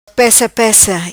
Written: Pessa Pessa

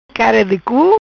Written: Cara di cu

